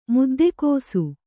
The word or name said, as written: ಮುದ್ದೆಕೋಸು